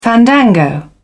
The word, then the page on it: fandango: British English